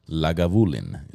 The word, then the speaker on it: Lagavulin: It's called Lagavulin. Is that how you pronounce it? That's it.